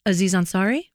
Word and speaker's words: Aziz Ansari: Aziz Ansari? – I've never been able to say it.